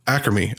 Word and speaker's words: Acrimony: Uh, number two, uh, Tyler Perry's Ar- Ar- Archemy? Archemy? I want to say Archemy. I hope I'm saying that right. Acrimony, acrimony, acrimony, acrimony. What, what was what is wrong? Acrimony. Tyler Perry's acrimony.